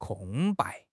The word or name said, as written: 空白